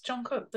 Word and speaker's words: Jungkook: No, but it's it's Jungkook. That's it. That's it's what whatever he was saying was just incorrect because he was like saying kook, right? – Yeah.